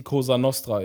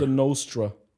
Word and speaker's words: Cosa Nostra: Zum Beispiel, du weißt, was die Cosa Nostra ist. Ja. Was sagen Amis immer? – Cosa Nostra. Costa Nostra. – Costa Nostra. Ist immer falsch.